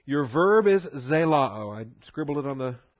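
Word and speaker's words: Zelao (ζηλόω): Z-E-L-O-O. Zelao. You got your short O followed by your long O. Your Omicron Omega ending. Zelao. Z-E-L-O-O.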